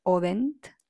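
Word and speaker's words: Michel Odent: Y tiene muchos libros, búsquenlo, Michel Audin, en español se escribe Audin, con T al final, tal como lo escuchas, Audin.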